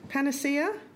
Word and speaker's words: panacea: The poo, I don't know how to pronounce this word, panacea? – I think so, yeah.